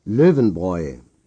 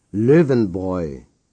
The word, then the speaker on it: Löwenbräu: Löwenbräu. – Say Löwenbräu.